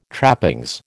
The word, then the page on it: trappings: American English